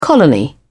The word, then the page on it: colony: British English